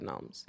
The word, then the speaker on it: gnome: and that's a second category of gnomes. – As in, okay. His name is Norm with a G, and they pronounce the G. Guys, the G in gnome is silent.